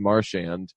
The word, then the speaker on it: Marchand: He said his name in context when I was talking to him for a story, and I was like, "Wait, is it Marchand?" And he was like, "Yeah, but it's no big deal." And I was like, "No, people are saying your name wrong. Then like people should know." So I just did like a little blog post saying like, hey, I think we're all saying his name wrong. He said today that it's Marchand.